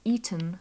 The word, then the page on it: eaten: English